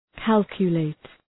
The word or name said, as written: calculate